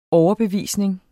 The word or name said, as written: overbevisning